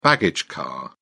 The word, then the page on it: baggage car: British English